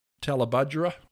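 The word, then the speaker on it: Tallebudgera: Talabudgera. – Talabudgera. – Was that right? – Yeah, that's how it's pronounced, but I don't live there.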